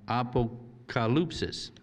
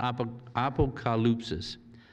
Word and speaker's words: apokalypsis: So the title Revelation comes from the first word in the Greek text, apokalypsis, Apocalypsis. Yeah, you pronounce it apocalypsis. Apocalypsis. Yeah, the accent is on the ka.